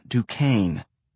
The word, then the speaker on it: Duquesne: Lena Duquesne. Pronounced in the South to rhyme with rain. The way Amma rolled it out, you would have thought the word had an extra syllable, Duquesne.